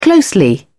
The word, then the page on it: closely: British English